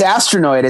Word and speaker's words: Astronoid: Yeah, it's Astronoid. It's exactly how it sounds.